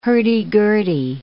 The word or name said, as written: hurdy-gurdy